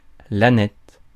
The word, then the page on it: aneth: /a.nɛt/; France